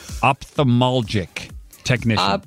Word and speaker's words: ophthalmologic: And by the way, I'm pretty sure I said that wrong, which is why I said it fast. Ophthalmologic technician. – Ophthalmologic. Yeah.